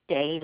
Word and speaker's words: Day: It's Tina, T-I-N-A. And the last name is Day, like night and day, D-A-Y.